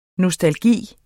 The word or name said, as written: NOSTALGIEN